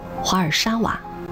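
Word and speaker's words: Warszawa: 华沙在波兰语中念作华尔沙瓦，这个名字是为了纪念一名叫华尔西和沙娃的恋人，他们冲破重重阻挠，最终结为夫妻的勇敢精神。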